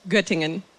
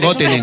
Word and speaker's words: Göttingen: Göttingen. – No puedo pronunciar Göttingen. – ¿No puedo pronunciarlo? – Göttingen.